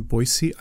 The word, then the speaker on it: Boise: So Crystal and I and our group of children now live in Boise, Idaho. And I learned that you pronounce Boise, Boise, not Boise, because I don't know.